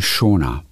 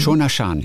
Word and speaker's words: Xiaona: Tischtennis, Shan, so, und die wird geschrieben X-I-A-O-N-A, Xiaona. Und ich glaube, das wird ausgesprochen Shona, meine ich. – Oh. – In Erinnerung zu haben. Mhm. Shona Shan hieß die.